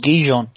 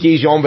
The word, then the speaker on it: Gijón: Yeah, I thought it was Gijón. – It is Gijón, but everyone loves pointing out, just going, yeah, the donkeys, Sporting Gijón.